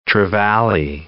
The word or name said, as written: trevally